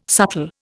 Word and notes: subtle: Indian

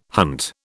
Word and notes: hunt: British English

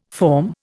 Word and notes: forme: Indian

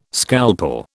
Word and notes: scalpel: British English

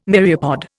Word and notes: myriapod: Indian

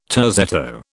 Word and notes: terzetto: British English

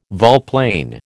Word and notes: volplane: American English

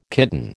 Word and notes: kitten: American English

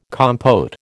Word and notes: compote: American English

